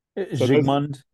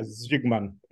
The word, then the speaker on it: Zsigmond: Zsigmond, is that how you say his name? – Zsigmond, yeah. – Yeah, Zsigmond.